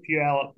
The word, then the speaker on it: Puyallup: So the Armed Forces Initiative Washington has a 3D archery shoot at the Tacoma Sportsman's Club in Puyallup, Washington. Probably didn't say that right. – Puyallup, yeah.